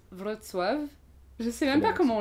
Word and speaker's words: Wrocław: Alors ça s'écrit W R O C L A W, donc W R O C A W. – Okay. – C'est L-A-W. Enfin, bref. En fait, c'est une orthographe un peu bizarre parce que c'est les lettres polonaises. Quoi, et genre le C, tu le prononces T, le L, c'est un L barré, donc c'est W. Bref, voilà, donc c'est Wrocław.